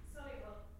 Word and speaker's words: Solihull: Solihull.